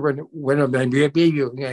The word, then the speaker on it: Wembanyama: Of course, this week, the NBA draft, and the big guy, Victor Wembanyama. I can try to pronounce it, folks. I'm not going to. – Wembanyama. – Wembanyama. Okay. So, anyways, number one, the San Antonio Spurs.